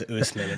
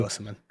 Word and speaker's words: Osimhen: Usman Dembele. All right, let's keep it going. – Literally, Kyle, every single time he says Usman instead of Osimhen.